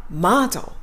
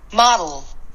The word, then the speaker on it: model: モデルはマード最後に辞書の発音もう一回だけ聞きますね。マードマードね。